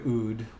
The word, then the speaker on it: oud: And the word oud, which rhymes with food, it's spelled in English, and dude. In English, we spell it O-U-D, but it comes from the two Arabic words, al-oud, which means the wood.